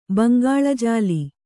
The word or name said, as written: ಬಂಗಾಳಜಾಲಿ